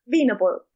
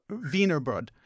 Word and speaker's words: Wienerbrød: Instead, you have something called Wienerbrød. Is that the correct pronunciation? Probably not. – But Wienerbrød. – Wienerbrød.